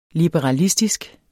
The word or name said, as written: liberalistisk